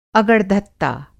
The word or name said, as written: अगड़धत्ता